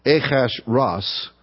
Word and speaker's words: Ahasuerus: In the reign of Ahasuerus, I think the way you pronounce it is Ahasuerus. That's the literal pronunciation, Ahasuerus. Also known to us in history as Xerxes.